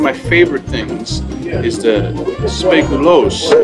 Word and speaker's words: speculoos: Now, how do you say that again? – Speculoos. – Speculoos. – That's how you pronounce it in Dutch.